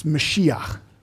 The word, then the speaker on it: Mashiach: Do you know what that word anointed sounds like in Hebrew? It's Mashiach. We would pronounce it Messiah.